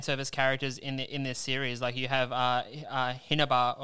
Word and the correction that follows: Hibana: or how is it pronounced? – Yeah, Hibana.